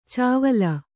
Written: ಚಾವಳ

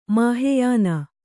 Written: ಮಹಾಯಾನ

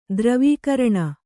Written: ಧ್ರುವೀಕರಣ

